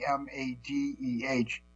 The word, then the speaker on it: Hamadeh: The measures offered by Republican Abe Hadalite, let me pronounce his name, Hamadeh, Republican of Arizona, were adopted in a tactical air and land forces N block passage.